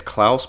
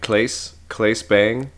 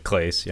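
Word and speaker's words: Claes Bang: I want to say it's Klaus, but that's not right, Klaes Bang. – I've always said it Klaes, yeah.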